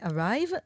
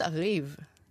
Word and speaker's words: Ariv: קוראים לזה Arrive, אבל uh, כותבים A-R-I-V. אני חושבת Arrive.